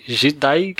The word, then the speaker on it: Jidai: É engraçado que, pela derivação do Jedi Geek, o Jedi em é, japonês, né, se escreve J-I, né, no Romand, né, trazendo para nossos caracteres, é J-I-D-A-I. Então a pronúncia seria algo como Jedi.